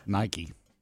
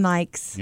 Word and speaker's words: Nike: You know, want to know the simple one that I mispronounced for years? – Yes. – Nike. – Did you call them Nikes? – Yeah. – Why? – Really? – Yeah. I called them Nikes probably into my 20s.